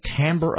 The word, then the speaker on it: timbre: It's like everybody says timbre, but it's pronounced timbre, the timbre of her voice.